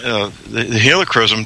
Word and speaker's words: helichrysum: Krillisum, is that, am I saying it right? – Yeah, helichrysum. – Yes. Helichrysum.